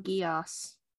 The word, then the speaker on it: Geass: Lelouch, and it's called Geass. – It looks like I've heard it pronounced as called Geese as well.